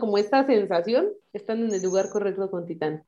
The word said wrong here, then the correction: Titane: Nada, nada, más para hacer la aclaración, es Titane, se escribe Titane porque no es como Titán de yo soy Titán.